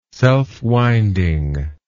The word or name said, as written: selfwinding